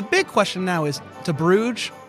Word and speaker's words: Bruges: Bruges.